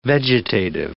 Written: vegetative

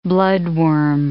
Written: bloodworm

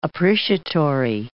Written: appreciatory

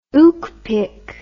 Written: ookpik

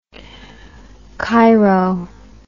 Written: Cheiro

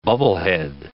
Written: bubblehead

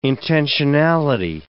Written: intentionality

